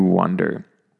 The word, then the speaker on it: wander: And the next word, I mentioned this one because they sound very similar, but be careful with the vowel sound. So the first one is wander, and the second one is wonder. So one is sort of wan, on, onder, and the other is wonder. Sort of like how you say the number one, I wonder. It's kind of similar to that pronunciation.